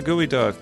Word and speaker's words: geoduck: I was hoping you would validate this and be like, oh, yeah, Gooey Duck. It's my favorite. – No. It's duck. It's actual duck? – No. – Oh. – They just call it that. It's spelled like geoduck.